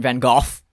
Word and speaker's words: Van Gogh: So Weather Report's walking down the street and he sees our best friend, Vincent Van Gogh, which is how you say it.